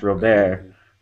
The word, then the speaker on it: Robert: In, uh, in Europe, in Europe, they don't have a problem with it because my last name is actually French, and it's pronounced in France, it's pronounced Robert which sometimes you'll hear Dave Brochus call me Taylor Robert.